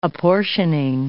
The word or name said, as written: apportioning